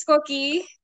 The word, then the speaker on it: Koki: My name's Koki. Like, that's how you say it.